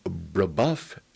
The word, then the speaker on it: Brebeuf: Now, you went to Brebeuf Jesuit Preparatory School.